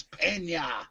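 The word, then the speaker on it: Peña: Say his name right. It's Pena. – Pena, like a colada. It's spelt the same.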